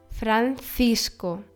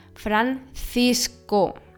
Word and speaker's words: Francisco: Wenn nach einem C ein I folgt, wird es weich ausgesprochen, wie ein Z. Ich meine Francisco. Das C nach dem O wird als K, also stark ausgesprochen.